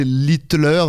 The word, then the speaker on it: Littler: Il s'appelle, enfin, ça doit se prononcer Littler. – Ouais. Ah, d'accord, ok. – Mais c'est L-I-T-L-E-R, quoi. – Ouais, ouais. – Très bonne, Littler, quand même.